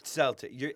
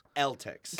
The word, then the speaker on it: Celtics: Celtics. It's a C. It's a hard C. – Cavs. – Celtics.